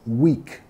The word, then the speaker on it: weak: Y se pronuncia igual que semana, ¿ok? Weak.